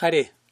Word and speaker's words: Hare: Pero incluso, aunque se refiera a Hari, no quiere decir que se pronuncie Hari. ¿no? En realidad es Hare.